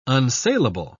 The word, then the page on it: unsalable: British English